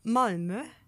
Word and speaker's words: Malmö: Normalerweise hast du ja sowas wie Uppsala, wo du die Betonung auf der zweiten Silbe hast. Ja. Aber bei Malmö ist halt ganz klar Malmö.